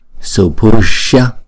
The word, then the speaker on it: supusha: Sumate subante subante, supusha supusha, không, chúng ta không đọc nhanh là push được, mà nếu mà đọc push thì chúng ta thiếu cái chữ hai nhịp của chữ u này nè, chúng ta phải đọc là supusha, không, chữ này là giống như là shia đó, shia shia shia shia, supusha supusha.